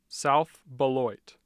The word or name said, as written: South Beloit